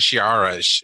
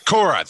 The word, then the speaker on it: Korra: It's not Shiara. It's Korra. – Korra. Thank you.